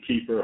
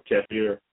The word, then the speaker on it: kefir: kefir or kefir, some people say pronounce it that, it provides a litany of health benefits for people who eat it.